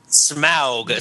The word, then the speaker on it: Smaug: It's pronounced Smaug, spelled S-M-O-U-G. – Smaug. S-M-O-U-G.